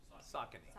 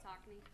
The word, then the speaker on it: Saucony: So there is a new scholarship program called Team Triumph, and it is by Fleet Feet in Saucony. Did I say that right? – Saucony. – Saucony, okay.